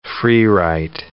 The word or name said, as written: freewrite